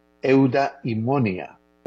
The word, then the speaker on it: eudaimonia: Eudaimonia es la pronunciación correcta. Eudaimonia.